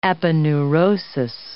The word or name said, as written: aponeurosis